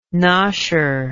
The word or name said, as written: nosher